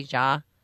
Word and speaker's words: Jha: Here's Dr. I always pronounce his name wrong, J-H-A, Ja.